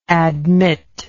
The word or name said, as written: admitter